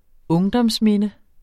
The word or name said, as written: ungdomsminde